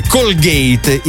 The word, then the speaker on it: Colgate: Il mio amico Max, lo scrittore, invece, ricorda da Clear, che in realtà si dovrebbe pronunziare Clear, così come in realtà avremmo dovuto pronunciare Colgate, il dentifricio Colgate.